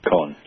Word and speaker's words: Cohn: Is that how you say it? Or Cohn, which is it? – Cohn, yeah.